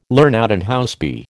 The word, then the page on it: LH: American English